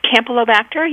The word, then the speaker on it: Campylobacter: It's Campylobacter. You got it right. And Pasteurella.